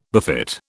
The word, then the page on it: befit: British English